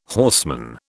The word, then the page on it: horseman: British English